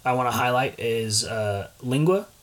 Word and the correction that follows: lengua: Eli, what is the correct pronunciation of lingua? – The correct pronunciation is lengua, which is essentially means beef tongue.